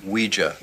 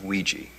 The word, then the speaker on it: Ouija: It's pronounced Ouija, not Ouija. It comes from the French and German words for yes, oui and ja, Ouija.